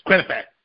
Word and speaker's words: Quinnipiac: Can't spell it, but it's Quinnipiac.